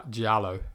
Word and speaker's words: giallo: But Giglio is like old Italian style, isn't it? It's like mystery. – Giallo. – Giallo, is that how you say it? – Yeah, Giallo. Oh, Giallo.